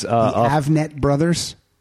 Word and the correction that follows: Avett: Avett. – How do you say it? – A-V-E-T-T-E.